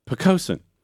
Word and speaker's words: Poquoson: Pocosin High School. – Please spell Pocosin. – Yeah, so it's a Powhatan word, which means Great Marsh. And it is spelled P-O-Q-U-O-S-O-N. – Pocosin.